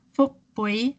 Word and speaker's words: Phuc Bui: Her name is spelled P-H-U-C space B-U-I. And it's pronounced Phuc Bui.